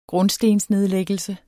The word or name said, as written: grundstensnedlæggelse